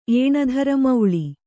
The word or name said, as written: ಏಣಧರಮೌಳಿ